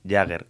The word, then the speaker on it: Jugger: Hoy tenemos aquí a mi amigo Pepe Calero y a Jordi Juárez, que han venido a hablarnos del Jäger. ¿Cómo, primero, antes de empezar, cómo se pronuncia o cómo se escribe el juego? Porque yo le he puesto J-U-G-G-E-R. ¿Lo he dicho bien? Perfecto. Correcto. A la primera.